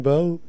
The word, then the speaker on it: Beau: It's spelled Bo, B-E-A-U. – Right. – Yeah. – Yeah, like Bo. Well, I guess Bo Duke that way?